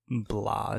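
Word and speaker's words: blå: I think in Swedish it's like Blaman, because I think Bla is how you say blue in Swedish.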